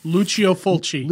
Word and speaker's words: Lucio Fulci: Ghost House, and 1981's The House by the Cemetery, directed by, and correct me if I'm wrong, I think his name is pronounced Lucio Fulci. Is that how you pronounce his name? It's Lucio Fulci. – Is it Lucio? – Lucio. – It's a soft C.